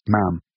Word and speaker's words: Ma'am: Thereafter, it's just Ma'am. Rhymes with ham.